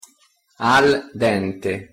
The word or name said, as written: al dente